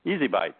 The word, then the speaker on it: ESI Bytes: You can also remember the show and website phonetically, as it sounds out as Easy Bytes of ESI information.